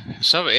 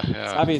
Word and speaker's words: SEVI: And the acronym we are talking about today is, and I'm not sure whether I, you know, say it correctly, SEVI. Or how do you pronounce it? – SEVI, yeah. – SEVI is correct.